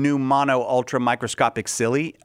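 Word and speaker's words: pneumonoultramicroscopicsili: I'm going to assume that PN in English is pronounced with a silent P. – Yes. – So I believe that this is new mono ultra microscopic silly.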